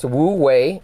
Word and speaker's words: Wu Wei: It's called Wu Wei, W-U-E-I. You could Google that if you want to read more about that. Pronounced Wu Wei.